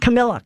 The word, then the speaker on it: Camilla: Um, Camila, no, how do you say it? Is it Camila? Camilla? Yeah, Camilla, Camilla.